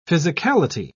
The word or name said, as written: physicality